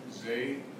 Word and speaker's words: Zeh: Yeah, my name's Art Zay. It's spelled last name Z-E-H.